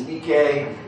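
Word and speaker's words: Nike (νίκη): The Greek is Nike. And in English we say Nike, and it means to subdue, either literally or figuratively, to conquer, to overcome, to prevail, to get the victory.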